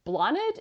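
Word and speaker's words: Blánaid: One of the daughters of one of the sisters, her name is, I think it's Blanid is how they pronounce it, but it's Blanaid. And her name's Blonid.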